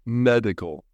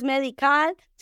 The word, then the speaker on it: medical: Single, medical. Muy bien, ve cómo no abre la boca en la A, no es medical, sino una boca medio cerrada, a ver.